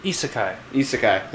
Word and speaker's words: isekai: It's kind of funny, and it's another Isaki style anime. – Isaki meaning the brought from another world. – Isekai. – There we go. Yeah, I've been saying it wrong for a while.